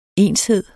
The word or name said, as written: enshed